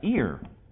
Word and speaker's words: aer: All right, but the transliteration is A-E-R, air. The pronunciation sounds like ear. Okay? So ear, that's how it should sound.